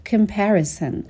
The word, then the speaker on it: comparison: Who generate bad feelings of comparison یعنی کسایی که حس بد مقایسه رو برای شما ایجاد میکنن. comparison به تلفظ دقت بکنین، صدای سه داره و در ابتدای کلمه هم شوار رو داره.